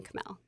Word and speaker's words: Chmel: It's spelled C-H-M-E-L. It actually means hops in German, like beer.